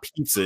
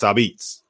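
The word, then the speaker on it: apizza: It's not pizza in New Haven. It's Abitz.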